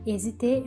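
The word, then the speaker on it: hésiter: Agora o verbo hesiter. Hesiter é um verbo que começa com H mudo, ou seja, o primeiro som é o som de uma vogal.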